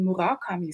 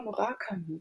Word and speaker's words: Murakami: Ich habe den immer schon geliebt, aber als Haruki Murakami. Und dann war ich kürzlich beim Schweizer Fernsehen, wurde ich angefragt, ob ich in einer Runde über dieses Buch diskutieren möchte. Und dort hat man mir dann gesagt, dass man eben Murakami sagt, dass alle das falsch aussprechen.